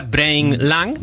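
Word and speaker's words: BrainLang: Se pronuncia Brain Lang. Escrito en español sería Brain Lang.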